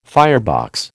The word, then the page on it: firebox: American English